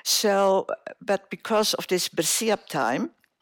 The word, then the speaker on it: bersiap: But okay, during this, after the war, during this, we called it bersiap.